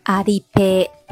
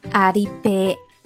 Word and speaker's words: アリペイ: 那支付宝在日语里，我们把它读作阿里 pay，阿里 pay。